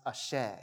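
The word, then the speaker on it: àṣẹ: It's pronounced ashe. Amen and ashe.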